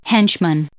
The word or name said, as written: henchman